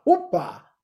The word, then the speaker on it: Opa: Opa, Opa. Opa. – I have no idea what you're saying, but sure. – Anyway, Opa Hour, Opa, Oprah without the R.